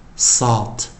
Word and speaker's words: salt: 我们在讲的首音就是 s，s 的发音也是跟自然拼读跟国际音标也是一样，s，s，s，salt，salt，salt，啊，注意啊，a 加 l 就念 o，o，o，再一遍啊，h，我们的发音为 h，h，hot，hot，hot。 s s s s salt salt salt。